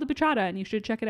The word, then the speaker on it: bachata: So on the TikTok episode, I talked about this Latin dance and I called it the bachata, but it's actually pronounced bachata. – Ooh, like horchata. – Right. With a B.